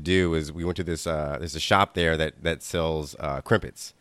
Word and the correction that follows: crumpets: Crumpets. Yeah, crumpets. So it was. – Yeah, you pronounce things weird. It's Adele, I say Adele, you say Adele, you say crimpets, I say crumpets.